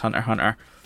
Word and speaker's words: Hunter x Hunter: So anyway, without further ado, at number 25 is Hunter, or Hunter x Hunter. It's meant to be pronounced Hunter.